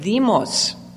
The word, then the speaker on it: demos: And then it also consists of the word demos. In Greek, the D is not pronounced like D. It's a hard TH. Demos.